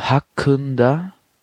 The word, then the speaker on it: hakkında: Beim hakkında müsst ihr aufpassen. Und zwar, das I ist ohne Punkt geschrieben im Türkischen. Und das bedeutet, dass es, wie soll ich sagen, sehr stumpf so ausgesprochen wird. Also mehr so ein Ö statt ein I. Und also hakkında. So wird es ausgesprochen.